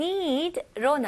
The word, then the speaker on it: need: 근데 need는 깔끔하게 이름절로 그리고 마지막 소리는 d 소리로 끝내셔야 돼요. 그래서 need, 으 소리가 나오면 안 돼요. Need, need. Okay?